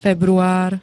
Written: február

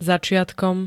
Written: začiatkom

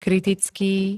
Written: kritický

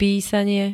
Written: písanie

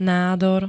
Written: nádor

